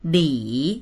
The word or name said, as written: li3